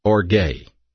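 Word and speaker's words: orge (ὀργή): The word wrath, if we go back to the Greek New Testament, is a word pronounced orge, O-R-G-E, orge, and it simply means the punishment of God.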